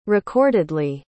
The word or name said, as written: recordedly